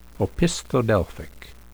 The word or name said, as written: opisthodelphic